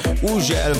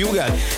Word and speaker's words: Hugel: Így van, az úriember, akinek minden országban másképp ejtik a nevét. Van, ahol Ugel, van, ahol Hügel, van, ahol Ugel, meg mindenféleképp, de nálunk úgy mondjuk, hogy Hugel, és Salto és a Jamaican Bam Bam érkezik, a csavarjátok fel a hangerőt.